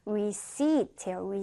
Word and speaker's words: receipt: 영수증은 receipt예요. receipt. 자, 지금 발음과 그리고 스펠링이 좀 다른 단어였죠? 여기 한번 적어볼게요. 5번에 Receipt. 자, P는 발음하지 않는다라 그랬어요. Receipt. 이렇게 발음이 됩니다.